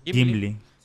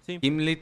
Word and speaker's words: Gimli: ¿Gimli? – Gimli. – Gimli.